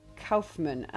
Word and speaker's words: Kaufman: We'll also be talking about the new Charlie Kaufman. Am I pronouncing that surname correctly, Ian? – Yes, you are.